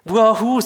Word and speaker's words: Wuhu: Wie spricht man es richtig aus? Weiß es jemand? Wuhu, super, Dankeschön. Wuhu, Winterdorf.